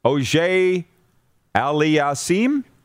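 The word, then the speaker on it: Auger-Aliassime: Did I say that right? No, you didn't. Is in third round action tonight at the Western and Southern Tennis Tournament in Cincinnati. Auger Aliassime? Yes.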